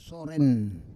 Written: soren

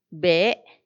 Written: bee

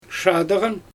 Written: ʃaadɯɣan

